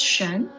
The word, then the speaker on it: Xuan: I'm Shen. Nice to meet you. Yeah, it's always awkward to start with that. my name is Shen. It's spelled as X-U-A-N.